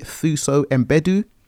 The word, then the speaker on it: Thuso Mbedu: I want to say her name right. Is it Thuso Mbedu? – Thuso Mbedu. She plays Nori, and she is a surprise star of the film, in my opinion.